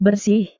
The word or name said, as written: bersih